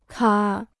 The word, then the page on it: 卡: kǎ